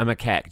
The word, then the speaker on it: macaque: It's a Japanese monkey, and it's called a macaque. A macaque? Yeah, I remembered. A macaque.